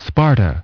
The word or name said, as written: Sparta